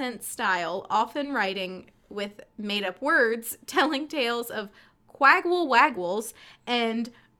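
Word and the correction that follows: Quangle Wangle: That is not how you pronounce that made-up word. – Quaggle wag... – Quangle wangles, I'm almost certain, is how it's pronounced.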